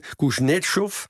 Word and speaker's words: Kuznetsov: Het boek is geschreven door Anatoly Kuznetsov. Je moet in het Russisch de nadruk leggen op de tweede lettergreep, maar ik ga het maar eenmaal doen. Anders denken mensen dat ik gek ben. Kuznetsov.